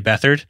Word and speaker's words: Beathard: And so it's either going to be Mullins or CJ Beathard. By the way, I used to think you pronounce Beathard Beathard. So I'm like, I have to always very much remind myself not to say CJ Beathard.